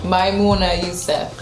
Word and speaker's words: Maimouna Youssef: Maimouna Youssef.